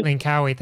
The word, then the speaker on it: Langkawi: Langkawi, thank you.